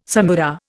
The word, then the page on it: samara: Indian